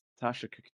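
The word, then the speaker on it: Tashikk: Yeah, with two K's. – Tashik, they pronounce both K's.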